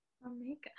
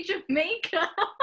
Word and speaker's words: Jamaica: Jamaica. That's in the book that I'm reading to this, my kids, and I don't know how to say it. So I say Jamaica. – Oh. Well, it's Jamaica.